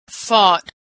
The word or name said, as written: fought